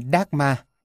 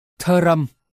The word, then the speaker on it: Dharma: Người Anh, Mỹ đều đọc là Dharma. Đến mức người Ấn Độ khi nghe thấy thế cũng sửa theo, cũng nói là Dharma, để cho người nước ngoài dễ hiểu. Thực ra, nó phải được phát âm đúng là Dharam, ba phụ âm thờ, r, m đặt cạnh nhau, mà không có nguyên âm chèn giữa.